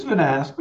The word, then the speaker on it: Vanasse: So Harold Vanas, did I say that right, Harold? – It's Vanas. – Vanas? Okay, yeah. Close enough. – Sorry, your way was fancier than my way.